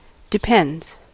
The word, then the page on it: depends: American English